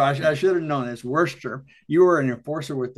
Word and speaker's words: Worcester: Worcester. You say Worcester. We say Worcester.